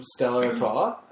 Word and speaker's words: Stella Artois: Like, Stella Artois, I think, had a huge...